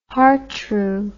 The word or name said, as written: hartru